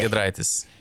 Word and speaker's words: Giedraitis: You know, I might mess up the name, but Gytiraitis? Oh, Brotas Giedraitis.